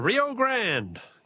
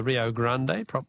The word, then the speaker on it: Rio Grande: The Rio Grande, yes, or the Rio Grande, that's to give it its Spanish pronunciation.